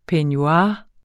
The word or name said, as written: peignoir